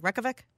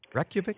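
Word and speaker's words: Reykjavík: Am I saying it right, Ken? Reykjavik? – Reykjavik? – You did it right.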